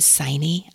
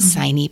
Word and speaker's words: Signe: Her name is, I'm going to pronounce it Signe. I don't know how to pronounce it in Norwegian, but Signe Pike. The author spells it the same way, and that's how she pronounces her name. That's what we're going to go with.